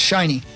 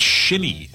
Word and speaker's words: Shinnecock: That's a I believe it's pronounced shiny. – It's shinny. – And then, ends. Shinny. – Shinnecock.